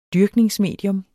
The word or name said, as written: dyrkningsmedium